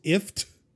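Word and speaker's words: IFTTT: Although, did you know that if this then that is pronounced ift? – Yeah.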